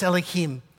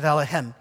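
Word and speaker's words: Elohim (אלוהים): When you see the word Elohim, now we, why is it pronounced Elohim? You don't have to. You can pronounce it Elohim. But Elohim is the way the, the Jews would pronounce it. That's the way of making it plural. El is singular, Elohim, plural. Every time you see it, think artist, glory, splendor, ruler, omnipotent.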